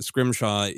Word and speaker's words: Scrimshaw: And then most people are great about just Scrimshaw is spelled exactly as it's, you know, would be pronounced.